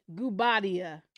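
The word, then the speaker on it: Guobadia: She announced her engagement to Simon Gubadia. – That looks about right. – That's how you say it.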